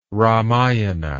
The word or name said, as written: ramayana